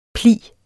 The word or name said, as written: pli